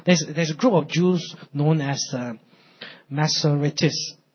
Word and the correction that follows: Masoretes: I hope I spell it, I mean, I pronounce it right. Masoretes, right?